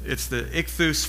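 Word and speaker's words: ichthus (ἰχθύς): And it's the ichthus fish. And the reason it's ichthus is because that's what that says in Greek. That's kind of how you pronounce that.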